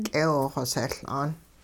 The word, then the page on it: k'eewxoseL'aan: hupa